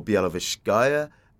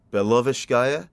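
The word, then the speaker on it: Białowieża: So sometimes it's referred to by its Polish name, which is Bialowieza or Bialowieska. And sometimes it's pronounced in the Belarusian, which is Bialowieska.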